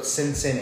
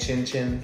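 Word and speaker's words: Cin Cin: Yeah, it's C-I-N-C-I-N. I pronounce both Sinsin and Chin-Chin.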